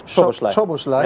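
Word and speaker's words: Szoboszlai: Das ist, und da sind wir wieder bei der Personale Csoboszlai. Mhm. Richtig ausgesprochen? – Csoboszlai.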